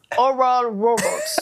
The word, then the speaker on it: Oral Roberts: Vous avez aimé ma prononciation de Oral Roberts? – Oui, c'était parfait. Bravo, bravo. – Je t'ai entendu, peux-tu refaire? – Oral Roberts.